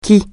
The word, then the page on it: qui: francais